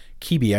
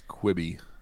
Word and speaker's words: Quibi: Kibi. I think it's pronounced Kibi. – I think it's Quibi. Because it means like quick bit. Quick bites.